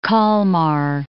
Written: Kalmar

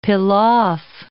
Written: pilaf

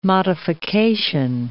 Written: modification

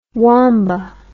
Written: womba

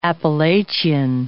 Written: Appalachian

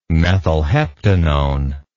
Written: methylheptanone

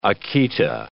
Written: Akita

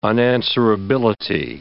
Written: unanswerability

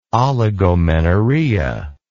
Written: oligomenorrhea

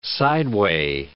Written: sideway

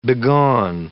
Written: begone

